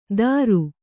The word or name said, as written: ದರು